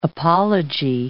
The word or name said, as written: apology